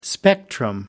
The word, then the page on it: spectrum: American English